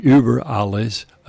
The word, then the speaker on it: Über alles: Most people would call it Über alles because it's a U that can be pronounced differently with the umlaut that the Germans put over it. We don't have an umlaut in our language, so we don't have that sound.